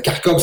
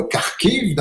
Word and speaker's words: Kharkiv: Kharkov, c'est la ville qu'on connaît aujourd'hui sous le nom de Kharkiv dans sa prononciation euh, ukrainienne.